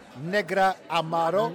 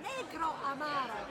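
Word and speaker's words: Negroamaro: Tell me how to pronounce it. – Negro Amaro. – Negra Amaro. – No, Negro Amaro.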